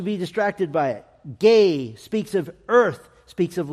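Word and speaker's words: gay (γῆ): It's made up of the Greek letters gamma and eta, and we would pronounce it gay.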